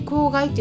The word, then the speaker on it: 香害: 香りの害と書いてね香害って読みますけれどもあのこれとねアロマセラピーとねごっちゃにしないでもらいたいなと思います。